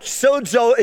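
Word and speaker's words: sozo (σῴζω): And the Greek word being saved is the word, I'll spell it to you first, S-O-Z-O. F-O-Z-O. This is a Greek word. And it's pronounced sozo. Sozo.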